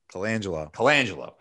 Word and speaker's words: Colangelo: Colangelo.